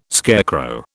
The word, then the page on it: scarecrow: British English